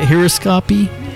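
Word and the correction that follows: haruspicy: Haruspicky. – Haruspicky. He does his haruspicky.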